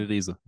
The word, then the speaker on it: Reese: Äh, Reese, Fabian Reese.